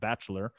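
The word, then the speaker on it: Batchelor: There's a T in there, but I guess it would still be pronounced Bachelor.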